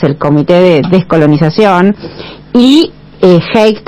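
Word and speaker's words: Hague: La histórica interpelación al canciller, no sé pronunciarlo, Haig, Alicia Castro, buen día. – Buen día, sí, canciller Haig, William Haig.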